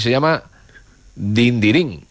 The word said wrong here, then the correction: Dindirindín: Dindirindín. – Ah. – Nos falta una de.